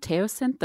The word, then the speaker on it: teosinte: As we kind of transformed how was it pronounced? Teosinte, the original corn? – Yeah.